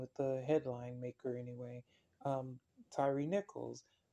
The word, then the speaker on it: Tyre: Although they pronounce his name Tyre, the place is pronounced Tyre also, I suppose, but as far as I know, it's pronounced Tyre, just like tire on your car.